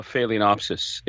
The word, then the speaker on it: phalaenopsis: Yeah, Terry, I have a phalaenopsis. Is that the proper pronunciation? – Very good.